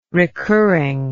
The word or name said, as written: recurring